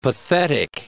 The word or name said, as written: pathetic